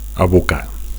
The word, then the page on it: abocar: Catalan